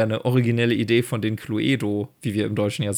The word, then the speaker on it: Cluedo: Und die korrekte englische Aussprache ist tatsächlich Cluedo. Also mit diesem O am Ende, was halt, ne, wie wir gerade herausgefunden haben, die englische, dieser englische Akzent im Lateinischen ist.